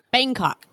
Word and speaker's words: Bangkok: Is it Bangkok? Am I saying that right? – Bangkok.